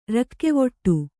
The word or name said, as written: ರಾಗವಿಡಿ